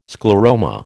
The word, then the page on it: scleroma: American English